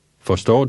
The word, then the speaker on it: forstår: Förstår du? Förstår du? Förstår du? – یادتان باشد که تأکید بر روی قسمت دوم این کلمه است.